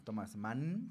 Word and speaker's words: Thomas Mann: Thomas, es que no se lo pronuncia bien, es alemán, Thomas Mann. – Sí, lo estás pronunciando bien.